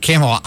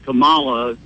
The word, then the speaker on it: Kamala: I think she's always pronounced it Kamala. I just call her Kamala after the wrestler. – Well, I'd always heard Kamala, you know, ever since You watched wrestling.